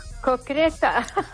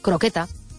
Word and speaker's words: croqueta: Se acepta cocreta porque como hay gente que lo pronuncia muy mal, pues entonces han decidido que vale igual. pero bueno, la palabra correcta es croqueta.